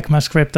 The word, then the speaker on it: ECMAScript: Uh, he, he supports SCMA, SCMA script modules. Uh, ECMAScript, I think it's usually pronounced.